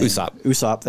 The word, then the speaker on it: Usopp: Usopp. – Thank you. I keep wanting to say Usopp, but it's not. Usopp.